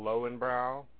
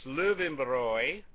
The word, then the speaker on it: Löwenbräu: I can tell you firsthand that what we drink here, that's called Lowenbrau, is very low. – Poop. Yeah, poop. It's pronounced Löwenbräu, and if you have it in München, it's some of the best beer you've ever had in your life.